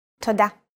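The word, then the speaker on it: Todah (תודה): Todah.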